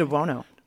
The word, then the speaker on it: DeBuono: DeBuono. – DeBuono. – DeBuono. – DeBuono. – DeBuono. – DeBuono. Kind of like Sonny Bono with a de.